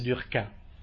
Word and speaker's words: Durkheim: E lui era talmente incazzato che, pur chiamandosi Durkheim, voleva che si pronunciasse Durkheim alla francese, perché non voleva niente a che fare con i tedeschi, tanto per chiarirvi un po' la figura, il personaggio.